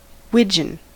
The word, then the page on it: wigeon: American English